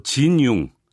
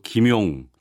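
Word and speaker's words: 진융 (金庸): 이 모든 영화의 원작자가 바로 진융, 한국식 발음으로 김용 작가입니다.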